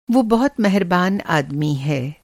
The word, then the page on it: मेहरबान: مِہربان